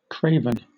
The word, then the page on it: craven: British English